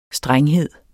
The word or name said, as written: strenghed